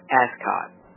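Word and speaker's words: ASCAC: African Civilization, pronounced ASCOT, clearly advocated this perspective in their writing.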